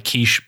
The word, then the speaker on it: Kiss: Sometimes it's shown like Kish Bella, but it's actually pronounced like our lovely breakfast egg pie in a quiche, but it's spelled like kiss.